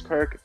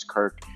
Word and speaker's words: Kirk: It's at fake James Kirk, TikTok at James Kirk zero one.